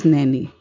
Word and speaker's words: Naini: As for my last name, it is pronounced as Naini. And it rhymes with words like many and penny.